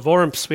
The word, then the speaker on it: Worms: It describes how Luther was invited to this imperial meeting, this diet, in a city called Worms. We have to say it like Germans so you don't think of worms.